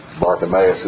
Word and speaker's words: Bartimaeus: Bartimaeus is the way I've heard it pronounced.